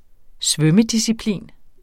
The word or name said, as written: svømmedisciplin